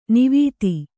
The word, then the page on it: ನಿವಾತ: kannada